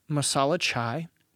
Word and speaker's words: masala chai: Their Marsala? How do you say that? Masala chai is the thing.